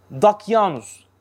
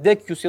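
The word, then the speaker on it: Decius: Ashab-ı Kehf zamanındaki Roma imparatoru Dakyanus. Aslında Dakyus da ama biz Türkçe'de Dakyanus, keza Arapça'da da o şekilde ifade edilir. Yoksa aslında Dekyus ya da Dakyus diye okunuyor. Dakyanus, duymuşsunuzdur değil mi bunu?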